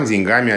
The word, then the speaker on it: деньгами: Но если повторить это, может быть, попроще, то современная норма с ударением на окончании. Деньги, деньгам, деньгами о деньгах. Ударение на первом слоге «деньгам, деньгами о деньгах» оно считается устаревающим или, может быть, даже уже устаревшим.